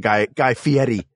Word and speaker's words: Guy Fieri: I'd like to release a public statement on Guy Fieri.